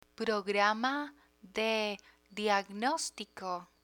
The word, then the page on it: programa de diagnostico: Spanish